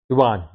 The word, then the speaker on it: yuan: Like how the Chinese yen is now devaluing quite rapidly. Am I correct on that? Yuan. Yeah, not the pronunciation. Japanese yen, the Chinese yuan.